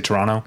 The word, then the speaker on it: Toronto: Speaking of Canadian cities, Torontonians don't pronounce the second T. – Toronto? – They just say Toronto.